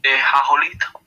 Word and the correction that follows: The Acolyte: di non credo che la pronuncia giusta. – The Acolyte, credo, credo si chiami The Acolyte.